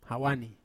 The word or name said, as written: hawani